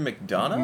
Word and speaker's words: McDonagh: It's Martin McDonagh.